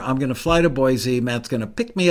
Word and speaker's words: Boise: or as they say in Idaho, Boise. It's funny, they pronounce it funny in their own state, but I guess they know what they're doing because they live there.